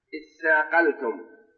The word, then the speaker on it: اثاقلتم: اثاقلتم إلى الأرض، اثاقلتم أصلها تثاقلتم، تثاقلتم تاء وتاء، فأدغمت التاء في التاء لتقاربهما، وجيء بالهمزة من أجل التوصل إلى النطق بالساكن، لأن الحرفين إذا أدغما فعند البدء بهما لا يتسمى إلا بإتيان حركة، إتيان بهمزة، وتسمى همزة الوصف. تثاقلتم، فأدغمت التاء في التاء فصارت لا يستطيع النطق بها المرء إلا بعد الإتيان بالهمزة، اثاقلتم، لأن الحرفين إذا أدغما أصبح حرفا واحدا مشددا، والمشدد أوله ساكن، والساكن لا يستطيع المرء أن يبدأ به، وإنما يأتي بمحرك بحركة قبله فيتوصل إلى النطق بالساكن، فصار اثاقلتم.